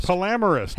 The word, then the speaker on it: polyamorous: I think you made that. I don't think you pronounced that correctly. – Well, yeah, that's how you pronounce polyamorist. – Polyamorist? – Polyamorous. He loves minis. – Whatever. – Minis people. – It's a polyamorous.